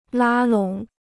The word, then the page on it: 拉拢: lā lǒng